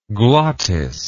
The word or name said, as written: glottidean